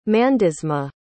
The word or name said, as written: mandisma